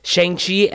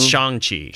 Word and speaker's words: Shang-Chi: It says it's pronounced like song, not sang. And I've been saying it wrong, like everybody else is. It's Shang-Chi.